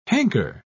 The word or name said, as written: hanker